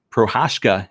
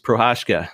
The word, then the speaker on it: Prochazka: Reyes versus, and I've got his name, Prochazka is how you pronounce it. It's Prochazka. But I went and figured out how to pronounce it earlier. It's Prochazka.